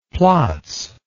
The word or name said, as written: plotz